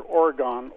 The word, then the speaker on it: Oregon: Because it seems like every time there was like a national political convention, just over and over you'd hear Oregon, Oregon, state of Oregon. And there was a T-shirt in the early to mid-70s that came out with Oregon spelled phonetically, which was O-R-Y-G-U-N.